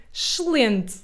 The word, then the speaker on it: excelente: Então, é bem verdade, nós comemos as vogais e essa palavra nós pronunciamos como excelente. Excelente. Então, nós comemos quase todos os S da palavra.